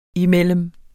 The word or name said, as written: imellem